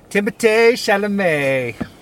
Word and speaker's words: Timothée Chalamet: Timothée Chalamet is in this movie, or the French pronunciation is Timothée Chalamet. That's just how they say it in France.